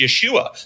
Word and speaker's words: Yeshua: Oh, and they feel relieved and use that for a couple of years until someone comes along and says, "Yeah, but that's not how it's pronounced. It's pronounced Yeshua.